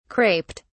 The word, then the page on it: craped: British English